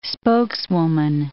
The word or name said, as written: spokeswoman